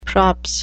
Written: props

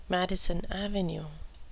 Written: madison avenue

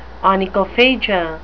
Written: onychophagia

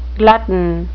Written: glutton

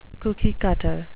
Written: cookie-cutter

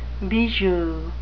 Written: bijou

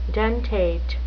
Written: dentate

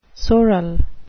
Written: sorrel

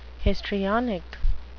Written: histrionic

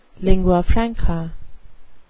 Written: lingua franca

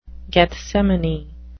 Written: gethsemane

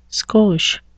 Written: skosh

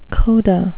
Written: coda

